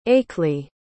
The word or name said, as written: aekley